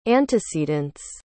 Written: antecedents

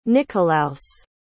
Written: Nickolaus

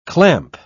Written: clamp

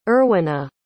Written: erwyna

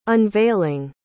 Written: unveiling

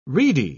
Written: reedy

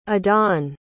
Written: adan

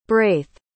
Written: braith

